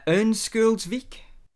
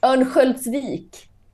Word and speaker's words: Örnsköldsvik: Ørnskjoldsvik? – Okay. This O is an E. Ørnskjoldsvik. – Okay, who was the... Well, it's pronounced Örnsköldsvik.